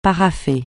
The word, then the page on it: parapher: francais